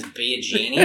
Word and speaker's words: Biagini: His last name is Biagini, and we thought it was just like spelled that way, but that's actually how it's pronounced. And, you know, why be Aladdin when you can be a genius?